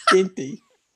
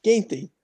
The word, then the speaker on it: Gaethje: Um, and then I will be going to the UFC where Khabib and Justin Gaethje. – Gaethje. – I, Justin I, Gaethje. I can never. I never remember how to pronounce. That's quite the name. list. Justin Gaethje. Ginty. Ginty.